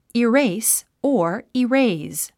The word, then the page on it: erase: iréis; イレイス